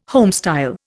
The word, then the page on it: homestyle: Indian